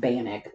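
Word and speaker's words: Bannack: Over the years, Banat slowly became abandoned as its inhabitants moved on to bigger cities and new opportunities. – I think that's pronounced Bannock.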